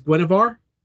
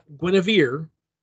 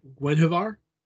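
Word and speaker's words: Guenhwyvar: Her name is Guinevar. Not Guinevere, but Guinevar. At least that's how I pronounce it.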